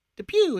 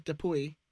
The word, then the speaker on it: Dupuy: By the way, our friend Travis, aka Off the Post, keeps saying we are pronouncing Dupuy wrong. Like it's Depew instead of Depuy.